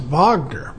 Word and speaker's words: Wagner: There's some 25,000 troops in it's pronounced Wagner because he named it after the composer of the Rings, Wagner.